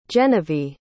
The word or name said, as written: genivee